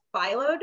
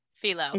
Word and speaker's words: phyllo: And the apple strudel itself calls for a type of dough called phyllo dough. I'm not even sure if I'm saying that correctly. Is it phyllo or phyllo? Do y'all know? – Phyllo. – It's phyllo.